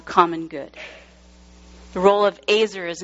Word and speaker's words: ezer: It's spelled E-Z-E-R, but that E has an A sound, Azer.